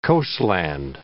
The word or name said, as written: coastland